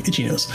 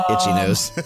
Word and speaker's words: Ichinose: Or it's probably like Itchy Nose or something like that is how you say his last name, probably. – I-C-H-I-N-O-S-E. Itchy Nose. – Yeah. Itchy Nose.